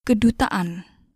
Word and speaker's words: kedutaan: Kedutaan. Kedutaan. Kedutaan. – Great. Notice the stop before the an at the end.